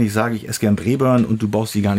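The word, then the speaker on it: Braeburn: Ja, Breburn ist schon richtig ausgesprochen.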